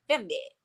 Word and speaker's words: Fernweh: But it's Fernweh. – Astho is like the German. She's like Fernweh.